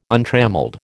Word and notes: untrammelled: American English

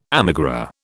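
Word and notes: émigré: British English

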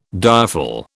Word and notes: direful: British English

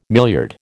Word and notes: milliard: American English

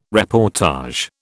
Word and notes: reportage: British English

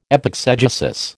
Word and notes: epexegesis: American English

